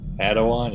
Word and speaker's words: Padawan: Now the next word the Padawan, is that how you say it? – Padawan, yeah.